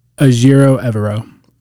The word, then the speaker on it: Ejiro Evero: I don't want to butcher his name live on air, but he obviously he Ejiro Evero.